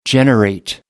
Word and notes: generate: American English

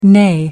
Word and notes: neigh: American English

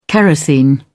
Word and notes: kerosene: British English

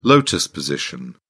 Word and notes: lotus position: British English

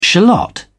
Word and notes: shallot: British English

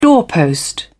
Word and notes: doorpost: British English

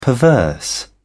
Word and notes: perverse: British English

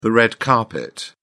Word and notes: red carpet: British English

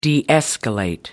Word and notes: de-escalate: American English

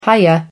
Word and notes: hiya: American English

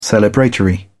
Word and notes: celebratory: British English

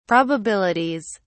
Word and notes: probabilities: British English

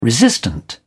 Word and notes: resistant: British English

